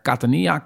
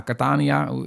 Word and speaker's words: Catania: Nou, daarna zijn we doorgegaan naar eh, Catania. Cata, ja, Catania, hè, nogmaals, ligt maar net waar je de klemtoon ligt.